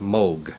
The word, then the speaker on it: Moog: In Germany, it's a fairly common name, and there it's pronounced Moog. – Robert Moog. Moog. – Pronounced Moog.